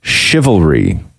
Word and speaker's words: chivalry: Not chivalry. Chivalry.